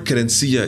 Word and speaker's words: querencia: The word querencia is defined in all kinds of ways.